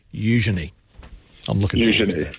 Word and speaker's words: Eugenie: At least you've got the name right. Heavy on the U, Eugenie. And then quick on the je, me. – Okay. Eugenie.